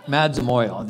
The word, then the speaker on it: Madzamoyo: But I'd like you to know that Madzamoyo, I got to tell you first that I constantly mispronounce that name. Madzamoyo. That's the way to say it.